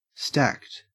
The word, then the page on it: stacked: Australian English